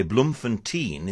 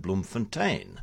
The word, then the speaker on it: Bloemfontein: So we say Bloemfontein instead of Bloemfontein, which is the correct pronunciation.